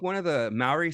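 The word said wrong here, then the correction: Mowry: sisters, Tia or Tamara, or Maury is how you pronounce it, were part of this, if I recall correctly.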